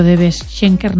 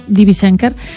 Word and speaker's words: DB Schenker: Ya he sabido pronunciarlo, ya me han dicho cómo hay que pronunciarlo, antes he dicho DB Schenker, ¿no? DB Schenker,